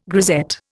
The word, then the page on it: grisette: Indian